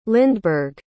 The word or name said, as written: lindeberg